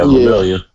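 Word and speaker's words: Lemillion: Real upset about my boy, what's his name, Lamillion? Is that his name? That's how you say it? – Yeah, Lamillion.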